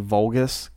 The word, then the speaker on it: Vulgus: And Valgus, his name may be reference to Volgus, Capcom's first game. – Very good. You said it right, like an adult. – I did good things. – You did good. – Capcom. – Vulgus.